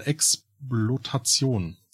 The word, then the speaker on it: Exploitation: Oder richtig ausgesprochen Exploitation.